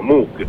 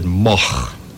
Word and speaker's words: Moog: Eh, Ways of pronouncing M-O-O-G. It's originally a Dutch name. This goes back, you know, a thousand years or so. Uh, and in, in, Holland you would pronounce it Moch.